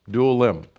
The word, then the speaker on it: Adullam: So that's how you pronounce it, Adullam.